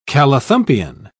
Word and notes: callithumpian: British English

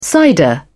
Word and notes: cider: British English

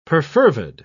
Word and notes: perfervid: British English

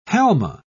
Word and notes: halma: British English

